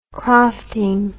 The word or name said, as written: crofting